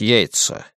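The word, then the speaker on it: Yeats: Ты когда-нибудь слышал о Йейтсе, Лен?» «По-моему, да», — ответил Крайтон, обдумав и отвергнув мысль о том, чтобы сказать Старке, что фамилия этого поэта произносится как Йейтс.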